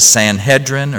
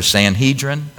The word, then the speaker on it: Sanhedrin: He was a member of the High Council, or what's called the Sanhedrin, or Sanhedrin. I've heard it pronounced different ways.